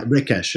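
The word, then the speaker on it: Recaș: I mean, just tell us how you came to buy the winery, you know, where you're based now, the Cremeli Rekash, is that how I pronounce it? – Rekash, yeah.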